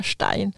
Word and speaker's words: Stein: Så vi hade häromåret en besök av, nej, det är flera år sedan nu, men Murray Stein, eller Stein kanske de kallas, han är amerikan, men jag uttalar hans efternamn på tyska, Stein.